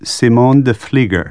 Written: Vlieger

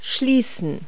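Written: close

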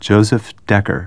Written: Decker

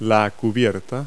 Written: canopy

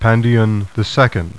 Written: PANDION

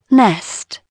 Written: nest

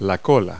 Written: cola

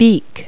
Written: beak

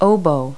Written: oboe

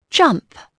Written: jump

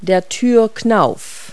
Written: doorknob

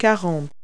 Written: forty

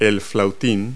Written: piccolo

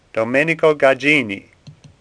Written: Gagini

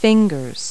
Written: fingers

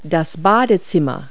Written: bathroom

